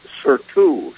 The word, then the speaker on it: surtout: All right, S-U-R-T-O-U-T. S as in sun. S-U-R-T-O-U-T. Again, I think it's pronounced surtout, but I'm not going to make any money on that.